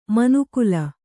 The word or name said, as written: ಮನುಕುಲ